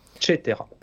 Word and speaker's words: Cetera: Donc ça s'écrit, euh, ça se dit Cetera, c'est ça Cetera. Cetera. Et ça s'écrit C-E-T-E-R-A.